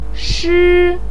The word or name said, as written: shi1